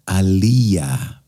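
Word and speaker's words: Aaliyah: Και το όνομά της προφέρεται σωστά στα Σουαχίλι Αλία Back and Forth.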